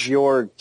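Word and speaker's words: Joerg: it's pronounced Jorg. – Yeah, it's Jorg, Bjorg. – Is that like Bjorn? – No, it's Jorg. – Jorg. – It's Jorg. It's like the Borg, but with a Y, it's Jorg.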